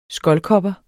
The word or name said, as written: skoldkopper